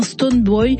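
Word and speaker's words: Stonebwoy: On va écouter la révélation Melissa et le patron de l'Afro-Dance Hall ghanéen Stone Boy sur le titre Show Me. – Absolument, et bravo pour la prononciation de Stone Boy.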